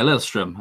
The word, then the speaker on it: Lillestrøm: is going to be Lillestrøm. Have I pronounced that correctly? – Yeah, Lillestrøm.